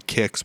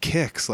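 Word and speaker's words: Qix: Kix? Like, what was that all about? You know? – It's spelled really weird, too. It's Q-I-X.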